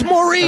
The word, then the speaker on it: Maurice: Barry, Robin, Morris. – Oh, you mispronounced. It's Maurice.